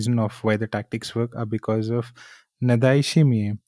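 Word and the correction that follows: Ndayishimiye: Did I say that right, Neil? – Just about. I think they call him Ndai Shimie. – Ndai Shimie.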